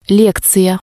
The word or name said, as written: lekziya